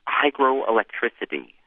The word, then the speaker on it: hygroelectricity: hygroelectricity, which is not hydroelectricity. It's spelled H-Y-G-R-O.